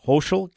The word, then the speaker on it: Hochul: Kathy, I believe it's pronounced Hochul, Kathy Hochul.